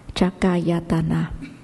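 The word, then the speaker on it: cakkhāyatana: Thì nhãn căn, 2 cái chữ được phối hợp lại thì đọc là chakayatana.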